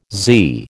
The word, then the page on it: Z: American English